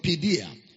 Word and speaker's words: paideia: Was translated from the Greek word paideia. Paideia is spelled as P-A-I-D-E-I-A, which implies training.